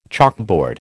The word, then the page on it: chalkboard: American English